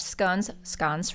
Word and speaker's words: scones: I would probably take a private jet to the London countryside and go horseback riding and have Earl Grey tea and fresh scones for those listening. That's the proper way to say it.